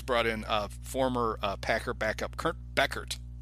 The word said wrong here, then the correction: Benkert: Benkert, oh. – Benkert, oh. – not Kurt Beckert, Benkert. I get that name wrong.